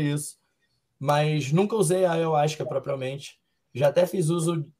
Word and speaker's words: ayahuasca: Quero ouvir falar sobre a experiência dele com a ayahuasca, assim pronuncia? – Cara, é ayahuasca. Tá faltando um A ali entre o Y e o U. É ayahuasca.